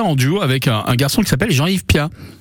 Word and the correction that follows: Plat: Alors Pierre-Yves Plat, pour être tout à fait exact. – Oui, j'ai dit Plat, ah, Plat c'est ça.